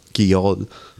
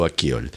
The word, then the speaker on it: Akyol: Obviamente, las pronunciaciones que yo he visto son más Akyol, más en otra forma de poner la garganta, pero digamos que no tengo entrenamiento de eso, así que se llama Gaye Su Akyol.